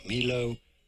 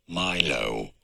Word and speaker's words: Milo: How do you say it? In both cases, it is to be said not Milo, but rather Milo. Milo. Pretty straightforward once you know Milo.